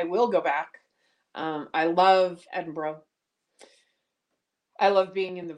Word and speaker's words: Edinburgh: Edinburgh. That's how it's pronounced in Scotland. I was very firmly corrected when I was there.